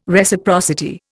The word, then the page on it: Reciprocity: Indian